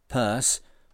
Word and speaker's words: Pierce: Captain Eben Pierce, pronounced locally as Purse, was his name.